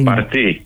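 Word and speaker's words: Parti: Το νέο κόμμα λοιπόν, αυτό το Γενή Παρτί ή Πάρτι, πώς το προφέρουν οι Τούρκοι? – Παρτί είναι. – Παρτί, καλά το φαντάστηκα, ναι, καλά το φαντάστηκα.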